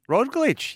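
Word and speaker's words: Roglič: Excuse my pronunciation here. – Yeah. – But is Primoz Roglic? – Roglic, yes.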